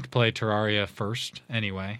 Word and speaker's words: Terraria: You can pronounce that however you can roll the R. Terraria.